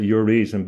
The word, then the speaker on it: Urizen: I'd pronounce it Urizen because it makes more sense, because it indicates the rational mind, the reasonable mind, referring to the the ratio, the Latin sense for reason, and also ratio in the mathematical sense, ratio.